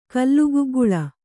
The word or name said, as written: ಕಲ್ಲುಗುಗ್ಗುಳ